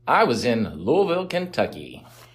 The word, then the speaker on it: Louisville: Yeah, the locals say you got to put rocks in your mouth to say the name properly. Yeah. Louisville.